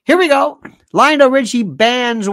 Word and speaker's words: Lionel: I said, it's Lionel. Rhymes with vinyl.